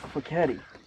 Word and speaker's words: Fochetti: Fochetti like machete, but with an F. But how do you say it if you're Italian? – Fochetti.